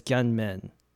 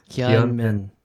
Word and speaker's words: Gyeonmin: The way you pronounce my middle name, my Korean middle name is Gyeonmin. Gyeonmin.